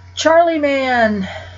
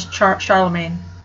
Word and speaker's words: Charlemagne: Charlie Man, also known as Charlemagne.